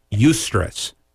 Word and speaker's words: eustress: Eustress. That's what benefit stress is called. It's just spelled like stress, but it's E-U-S-T-R-E-S-S. So it's stress with an E-U in front of it.